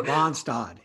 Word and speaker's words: Randstad: You say it Randstad? Or do you say bagel or bagel? Bagel or bagel? what are you? – Randstad, yes. – Randstad? Okay, we call it Randstad up here.